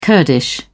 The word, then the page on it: kurdish: British English